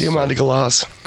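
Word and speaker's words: Diamanda Galás: Diamanda Galas.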